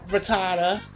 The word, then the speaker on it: Rattata: Yes, Run Rattata Run.